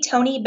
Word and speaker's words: Tonni: On LinkedIn, you can find me, Tony Bennett. My name is spelled a little weird, T-O-N-N-I, Tony Bennett at Terminus. Twitter's probably best, though. It's just Tony Bennett, T-O-N-N-I-B-E-N-N-E-T-T, all one word.